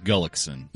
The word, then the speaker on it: Gullickson: Because I was under the impression that Glenn Gulitzin was spelled G-U-L-U-T-Z-A-N. – Right. – But according to Killer, Glenn Gulitzin is spelled G-U-L-L-I-C-K-S-O-N.